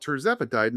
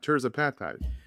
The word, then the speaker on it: tirzepatide: But they call it tirzepatide, not tirzepatide. – They put the emphasis on the different syllable.